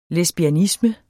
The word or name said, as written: lesbianisme